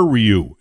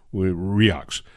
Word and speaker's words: Rioux: His name is Oliver Ryux. It's spelled R-I-O-U-X. It's a Swamper name, right? Ryux.